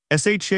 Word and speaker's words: SHA: SHA is often pronounced SHA, though spelling SH is also common.